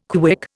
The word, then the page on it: KWIC: Indian